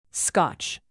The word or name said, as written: scotch